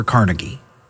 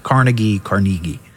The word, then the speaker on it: Carnegie: Or Carnegie. I've heard it pronounced both. Carnegie.